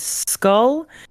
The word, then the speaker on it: Szkul: On TikTok, my name is Skull. It is spelled a little strange. It is SZ KUL.